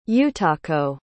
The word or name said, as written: utako